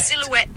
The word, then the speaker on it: silhouette: So the stress silhouette. Is on the last syllable.